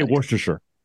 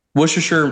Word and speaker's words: Worcestershire: How do you say it? – I say Worcestershire. – Worcestershire, that's how I do it too.